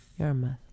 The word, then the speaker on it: Yarmouth: Yarmouth. Like math.